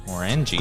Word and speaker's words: Glenmorangie: The what? Glenmorangie? – Morangie. – Glenmorange? Did you email about this? – Yeah. Yeah. A different year, but Glenmorangie. – Morangie. – Oh, we're saying it wrong.